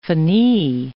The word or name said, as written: finis